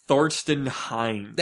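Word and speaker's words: Thorsten Heins: Well, as long as we're making fun of RIM, I want to tell you something about Thorsten Hines.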